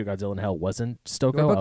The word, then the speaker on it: Stokoe: James Stokoe.